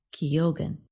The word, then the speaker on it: Keoghan: The last name of Irish actor Barry Keoghan, who starred in Saltburn, is pronounced Keoghan.